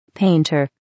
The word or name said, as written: painter